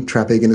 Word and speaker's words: Traphagen: Traphagen is spelled T-R-A-P-H-A-G-E-N.